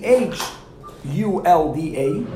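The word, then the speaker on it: Chulda: And if someone is not Jewish, or someone that wasn't educated as a Jew, then they're going to spell it with an H, like the Goyim do, Hulda, because they cannot pronounce a Ches.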